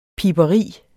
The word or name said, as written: piberi